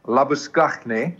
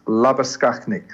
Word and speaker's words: Labuschagne: I'm going to say it like that because he's from the Free State. And he is Marnus Labuschagne. – Correct. – And I said to Harsha, I said, that's how you say it, Skakhne.